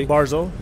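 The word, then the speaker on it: Barzal: Je euh, je sais pas si je le prononce comme il faut. Branzol, euh, le premier choix au total, euh, premier choix des Islanders avant Beauvillier l'an passé. Barzol. Barzol. Euh, a été extraordinaire.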